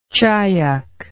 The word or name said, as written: chyack